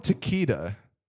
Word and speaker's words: Takeda: And then they call him Takeda. – It's not taquito. – Ooh, it's taquitos. – We have taquitos at home. – You don't pronounce K-E-K-E.